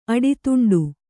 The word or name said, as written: ಅಡಿತುಂಡು